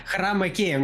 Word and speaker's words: хромакей: А, хромакей, мне понравилось твое ударение. Мне кажется, для киношников сейчас это именно так. Это хромакей.